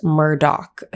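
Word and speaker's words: Murdaugh: And the first one is Murdoch. It is spelt Murdoch, but it is correctly pronounced Murdoch.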